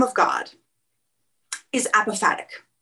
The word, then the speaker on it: apophatic: And anyway, could you explain what this apophatic, I can't pronounce it. – Apophatic. So it's apophatic.